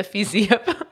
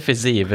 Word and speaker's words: Fiziev: och eh, jag, jag har fått det bekräftat att det heter fiziev.